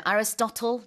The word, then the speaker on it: Aristotle: Aristotle. 이거 이제 우리는 아리스토텔레스라고 yeah. 하는데 발음 많이 다르죠.